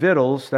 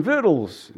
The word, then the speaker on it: victuals: Pa, I'm going to get some victuals. That's what it is. It's food, victuals. That's how you say it.